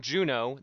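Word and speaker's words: Junod: He is Tom Juno. The D is silent.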